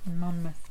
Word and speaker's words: Monmouth: The stress is on the first syllable, by the way. – So that was right. Monmouth. – Monmouth.